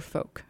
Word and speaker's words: folk: And also, do you say folk or folk? – I definitely say folk. – Okay. I've heard some people recently pronouncing the L, which has confused me.